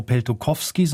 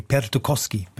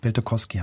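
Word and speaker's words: Peltokoski: Ich habe es vorhin falsch gesagt, er heißt gar nicht Tarmo Peltokowski, sondern ohne W, Peltokowski. Peltokowski heißt er.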